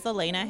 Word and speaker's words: Alaina: My name is Elaina Hanks, and my name is spelled A-L-A-I-N-A, and my last name is Hanks, like Tom Hanks.